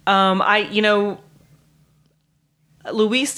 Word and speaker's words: Luis: as an American, we would say Louis. – yeah, probably. It is pronounced Luis.